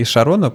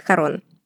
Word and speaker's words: Харон: В России обычно это тело называют Харон, не Шарон. Шарон это такой налет френча здесь появляется.